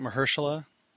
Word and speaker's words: Mahershala: Is that how you say it, Mahershala? – Yeah.